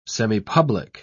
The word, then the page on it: semipublic: British English